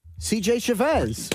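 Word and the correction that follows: JC Chasez: Wrong. JC Shazay.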